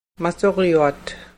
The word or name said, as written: masuryuat